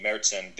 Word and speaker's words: Märzen: Well, a Märzen, the German pronunciation Märzen, the A has an umlaut over it, is an October, September style of beer.